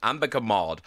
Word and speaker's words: Ambika Mod: Leo Woodall and Ambika Maud. Am I saying that? – You are, yeah.